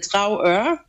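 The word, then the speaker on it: Dragør: To myślę, że dobrym wyborem byłoby Drauer, czyli taka dawna rybacka wioska, Jeszcze raz poprosimy nazwę. – Pisze się Drager, wymawia się Drauer.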